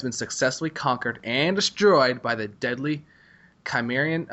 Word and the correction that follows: Chimeran: Uh, I cannot pronounce that name for some reason. Chimeran race. Chimeran. Chimeran. Thank you, Chris.